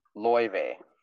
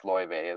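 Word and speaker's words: Laufey: So, and his mother is Loive. It's spelled Lao Fei, but it's Loive is how you pronounce it.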